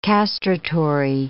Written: castratory

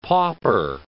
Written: pauper

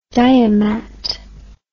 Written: diamat

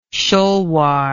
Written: shalwar

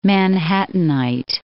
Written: Manhattanite

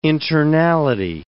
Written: internality

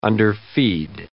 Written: underfeed

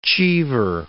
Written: Cheever

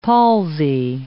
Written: palsy

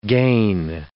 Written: gain

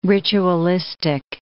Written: ritualistic

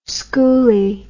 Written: schoolie

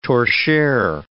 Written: torchere

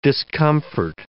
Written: discomfort

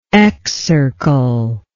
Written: excircle

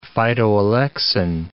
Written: phytoalexin

